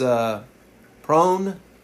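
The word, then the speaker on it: pounamu: These greenstone pendants are called pronaumi. And I'm probably saying that wrong, but it's pronaumu. It's P-O-U-N-A-M-U.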